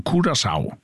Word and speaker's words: Curaçao: Och vi kommer att säga att det landanavnet Curaçao uttalas Cura, K-U-R-A-S-A-U av vårskan Curaçao.